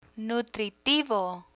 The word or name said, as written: nutritivo